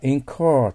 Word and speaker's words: incur: その次は incur となりますね。 cur というのは曖昧な発音ですよね。 car じゃないです。 r の発音がありますよ。